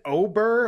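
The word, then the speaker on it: Åberg: I believe Ludwig Ober, apparently, is how you pronounce this guy's name. I always thought it wasn't that.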